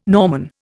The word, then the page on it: Norman: Indian